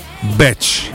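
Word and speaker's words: bitch: Ah, spiaggia è beach mentre donna dai facili costumi è batch.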